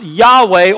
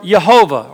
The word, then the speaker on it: Yahweh: And it would be pronounced Yahweh or Jehovah or Jehovah.